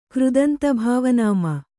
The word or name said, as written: ಕೃದಂತಭಾವನಾಮ